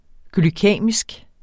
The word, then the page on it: glykæmisk: glyˈkεˀmisg